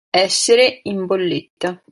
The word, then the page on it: bolletta: italian